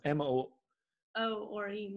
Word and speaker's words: Moore: How do you say Murr? M-O. – O-R-E, Moore.